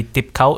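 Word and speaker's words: Thip Khao: So yeah, I would say what comes to mind would be Deep Cow in D.C.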